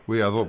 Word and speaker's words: Γουαϊδό: Η πρόταση που έκανε Γουαϊδό είναι. Γουαϊδό προφέρεται, ναι, είναι να θα δώσει αμνηστία στο Μαδούρο και σε ένα πυρήνα αξιωματούχων και ανώτερων.